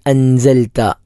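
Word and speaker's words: അൻസൽത (أنزلت): അല്ലദി അൻസൽത, അവിടെ സാ എന്ന അക്ഷരം സെഡിനോട് സമാനമായ അക്ഷരമാണ് അൻസൽത.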